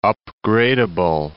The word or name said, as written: upgradeable